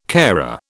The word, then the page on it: carer: British English